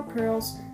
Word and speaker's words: Prlz: With each run, you'll acquire more pearls, pronounced pearl. P-R-L-Z, pronounced pearl, like pearl and then a Z, to spend on permanent upgrades to help make the next run a little easier.